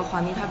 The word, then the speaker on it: Juanita: Juanita Beach 就 Kirkland 有一个 Juanita Beach，是 J-U-A-N-I-T-A、